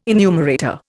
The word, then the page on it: enumerator: Indian